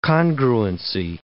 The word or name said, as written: congruency